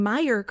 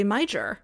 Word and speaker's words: Meijer: And, you know, Gray, I just want to say shout out for pronouncing Meijer correctly, because a lot of people south of the border will do a real hard J there and they'll say Meijer. And that's when I just know that they're not from around here.